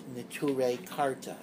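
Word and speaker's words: Neturei Karta: Neture Karta.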